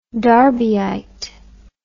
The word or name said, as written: derbyite